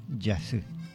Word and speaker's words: Yasy: Se escribe Y-A-S-Y, ¿no? Se escribe con la J inicial. Se pronuncia Yasé.